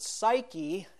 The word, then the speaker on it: psyche: Another interesting thing from the original, when you translate that verse into Greek, the last word in Greek is the word psyche, and it has a silent P at the beginning.